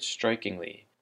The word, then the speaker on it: strikingly: So the word strikingly, it has the emphasis on the first syllable, strike, strikingly, and it's an adverb that can be translated as 显著的 or 显目的.